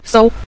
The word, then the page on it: sou: sou5